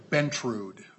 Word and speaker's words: Bentrud: Bentrud. – Bentrud. Boy, I really heard that wrong. – T-R-U-D.